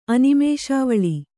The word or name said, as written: ಅನಿಮೇಷಾವಳಿ